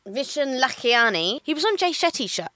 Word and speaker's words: Lakhiani: Yeah, Vishen Lakhiani.